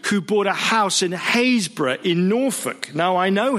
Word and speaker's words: Happisburgh: An outsider to Haysborough, by the way, it's spelt Happisburg, Happisburgh, but it confuses a lot of people.